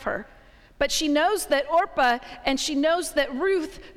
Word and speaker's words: Orpah: And this is where Naomi and her husband and her two sons take on two new family members, two Moabite women, Orpah. I did not say that wrong. She's not Oprah, she is Orpah.